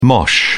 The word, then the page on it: mosh: British English